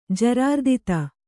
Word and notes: ಜರಾರ್ದಿತ: kannada

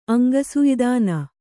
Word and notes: ಅಂಗಸುಯ್ದಾನ: kannada

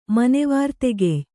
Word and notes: ಮನೆವಾರ್ತೆಗೆಯ್: kannada

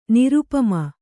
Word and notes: ನಿರುಪಮೆ: nirupame

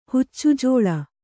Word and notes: ಹುಚ್ಚುಜೋಳ: Kannada

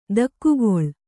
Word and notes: ದೊಗ್ಗಳೆ: kannada

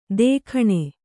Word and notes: ದೇಖಣೆ: dēkhaṇe

